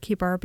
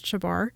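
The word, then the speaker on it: Chebar: And I hesitated on the word Kibar because I always pronounced it Shabar. And I looked it up, and apparently I had been pronouncing it wrong all my life.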